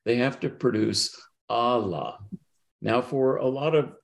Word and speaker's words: Allah: Now, in, uh, you know, almost everybody who works on the Sufis would, would uh, spell that a L L, long A H. Okay. And so Allah. But the problem is, is in Bengali you can't uh, you can't produce that sound. If I were to uh, transliterate the Bengali, it would be pronounced Ola. And I'm sorry, the the God of of Islam is not going to be, you know, understood very well as Allah, particularly given all the, you know, uh, in an English-speaking environment, what that invokes.